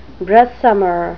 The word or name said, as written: breastsummer